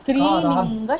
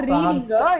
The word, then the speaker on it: strilinga: It is akaranta strilinga shabda. You have to focus on your pronunciations. It is completely wrong. – Ranta, I missed it. Ra. Okay. – And also, it's not rilinga, it is strilinga.